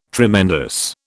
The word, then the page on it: tremendous: British English